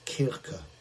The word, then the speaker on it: Kirche: Interestingly, the word church comes from an old English-German word pronounced Kirche, and in Scotland it was, of course, Kirk.